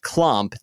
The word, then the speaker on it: CLOSMP: Listener Megan recommends Milkmaids or CLOSMP, which stands for Collective Listeners of Spilled Milk Podcast, which is pronounced clomp. The S is silent.